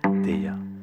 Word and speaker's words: Tya: Moi, je dis T-Y-A parce qu'on va le prononcer à la française. – Allez, ah, voilà. Ça me va.